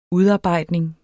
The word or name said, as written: udarbejdning